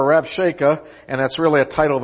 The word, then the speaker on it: Rabshakeh: And Rabshakeh said unto them, Brother Keith, how do you pronounce that word? – Rabshakeh sounds good. – Sounds. Rib-shacky.